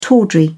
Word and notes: tawdry: British English

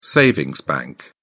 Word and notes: savings bank: British English

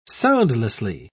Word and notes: soundlessly: British English

